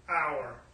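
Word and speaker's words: our (אור): It's pronounced as our. Kind of like our English word, O-U-R, it's spelled O-W-R, but it's pronounced the same, our.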